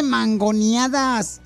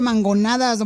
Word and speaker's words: mangonadas: que no se dice mangoneadas, se dice mangonadas.